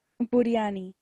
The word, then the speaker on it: biryani: how do you say it? Biryani? – Biryani.